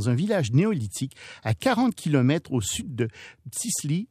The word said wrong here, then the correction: Tbilissi: Tbilissi. – J'arriverai jamais à le prononcer. – Tbilissi.